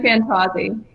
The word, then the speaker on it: Fantauzzi: It's Kathryn Fantauzzi.